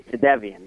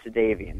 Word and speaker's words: Jadeveon: And I like to say Jadeveon, not Jadeveon. That's how you pronounce it.